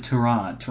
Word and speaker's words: Tyrod: You've got to pronounce it Tyrod.